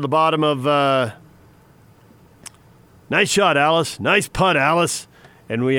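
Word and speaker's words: Alliss: Someone in the crowd yelled, nice putt, Alice. Because that's how you pronounced his last name, L-L-I-S.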